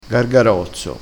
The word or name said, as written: gargarozzo